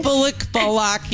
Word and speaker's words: Bullock: Sandra Bullock. – Did I pronounce her name? – I think it's fine. I think she won't mind. – Sandra Bullock. Isn't that how you pronounce her name? – Bullock.